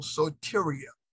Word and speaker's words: soteria: Now, this word salvation in the Greek is called soteria. It's called soteria, okay? Soteria in the Greek word is S-O-T-E-R-I-A. S-O-T-E-R-I-A. That's how you spell it, it's soteria.